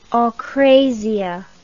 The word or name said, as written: akratic